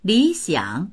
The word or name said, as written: li3xiang3